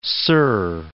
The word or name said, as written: Sur, Point